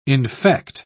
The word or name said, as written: infect